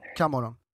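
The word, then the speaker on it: Camorra: Och visst var det också typ att den här drangetan, till skillnad från eh, kom, eh, Komorra, Gomorra, hur, hur man nu uttalar det. Kamorra.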